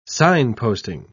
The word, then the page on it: signposting: British English